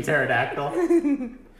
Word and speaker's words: pterodactyl: Did you pronounce the P in pterodactyl? – Yeah, she knows.